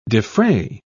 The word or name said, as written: defray